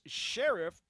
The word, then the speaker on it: Scherff: We really appreciate him taking some time to break down the Iowa Hawkeyes, who have some very solid prospects led by Brandon Scherff, and we now know how he pronounces his name. It is Scherff, despite the way it looks.